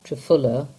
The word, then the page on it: Trefula: English